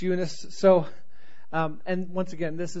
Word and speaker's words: Eunice: So Eunice, but you got to say it like this. You got to say Eunice. So Eunice. Oh, you say, oh, you nice and young, so you the mom.